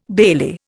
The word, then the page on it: bailie: Indian